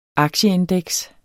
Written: aktieindeks

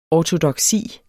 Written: ortodoksi

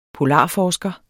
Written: polarforsker

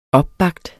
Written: opbagt

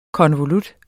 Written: konvolut

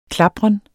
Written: klapren

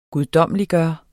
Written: guddommeliggøre

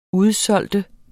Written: udsolgt

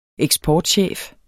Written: eksportchef

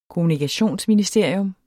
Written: kommunikationsministerie

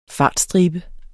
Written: fartstribe

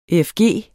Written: efg

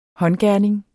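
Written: håndgerning

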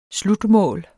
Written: slutmål

